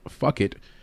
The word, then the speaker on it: Phuket: When you look at Tapology, it says he's out of Fuck It. Yeah, that sounds like I'm saying fuck it, but no, that's the pronunciation of the city.